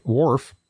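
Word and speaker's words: Whorf: And finally, in Talking Books, from Mike Worf, that's spelled W-H-O-R-F, I Love to Hear a Military Band.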